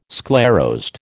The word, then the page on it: sclerosed: American English